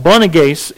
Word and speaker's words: Boanerges: I had to look up how to pronounce it, and I still messed it up when I was reading through it. But Boanerges is what this term is, and it means sons of thunder, and this is where they're called it.